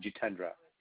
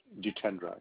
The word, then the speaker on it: Jitendra: Jay is my name, Jitendra. – J-I. – Jitendra. – Jakendra. – Jitendra, yeah. – So that's J. – J as in Japan, Itendra.